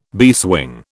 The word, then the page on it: beeswing: British English